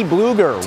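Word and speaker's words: Blueger: Nice pass by Letang here off the net to Teddy Bluger. Rhymes with Freddy Krueger.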